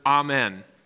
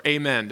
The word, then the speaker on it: amen: One of those words is the word amen, or amen, depending on how you pronounce it. It can be pronounced both ways, but today we often use the word amen at the end of a prayer or an expression of praise.